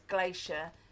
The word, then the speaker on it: glacier: My husband said he thought that I might like glacial erratics because glacier almost rhymes.